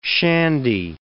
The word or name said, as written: shandy